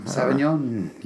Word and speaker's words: Sauvignon: And then a Skyside Cabernet Sauvignon or something. – Sauvignon, yeah. – Was that right? – That was right.